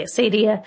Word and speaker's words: acedia: So, acedia, Acadia. People have pronounced this different ways. I'm going to say Acadia.